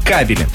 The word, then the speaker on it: кабели: Но правильно, если мы говорим про электрические сети, кабели. Далее.